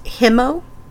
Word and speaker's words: Kimo: Kimo combines two Tiwa words, meaning mountain lion or king of its kind. – Mm-hmm. – That's where I found out it's technically pronounced Himo.